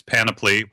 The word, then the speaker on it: Panoply: Panoply, yes. – Yeah. – We've many people even on our team are calling it Panoply, which sounds like a fun game, but that O is not there. It's Panoply, which many of us had to look up.